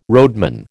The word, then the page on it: roadman: American English